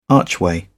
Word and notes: archway: British English

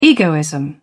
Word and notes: egoism: British English

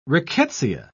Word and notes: rickettsia: British English

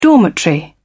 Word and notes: dormitory: British English

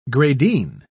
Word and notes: gradine: British English

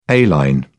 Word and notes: a-line: British English

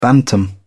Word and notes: bantam: British English

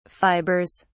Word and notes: fibers: British English

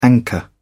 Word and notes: anchor: British English

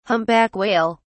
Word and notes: humpback whale: British English

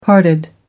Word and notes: parted: American English

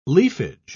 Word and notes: leafage: British English

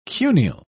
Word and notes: cuneal: British English

